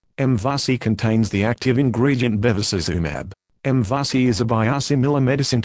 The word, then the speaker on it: Bevacizumab: Bevacizumab, pronounced Bevacizumab.